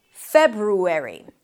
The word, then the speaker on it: February: The first is February. You start with an F sound, then it's the as in red, fe, you close your lips for a B, feb, then ru, an R sound and a tense U, febru, and then airy, like the ending of January, February.